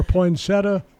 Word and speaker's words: poinsettia: First of all, how do you say that word, poinsettia? – Poinsettia. – Or poinsettia. Which is it? – I think there is an IA at the end, so it's poinsettia.